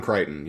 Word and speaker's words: Crichton: Yeah, uh, how do you pronounce like the the dude's name? John Crichton. Uh, uh, John Crichton. Yeah. Crichton. Yeah.